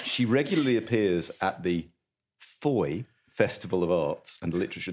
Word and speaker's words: Fowey: It's FOI to rhyme with joy.